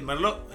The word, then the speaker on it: merlot: ¿Vos qué ves que el merlot? ¿Este merlot? – Robert Mondavi. – Merlot. No, pero esto es verdad, no estoy sanateando, ¿eh? La T en el merlot no se pronuncia.